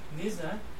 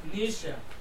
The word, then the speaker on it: Nietzsche: Nietzsche? Nietzsche.